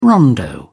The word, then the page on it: rondo: British English